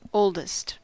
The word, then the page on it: oldest: American English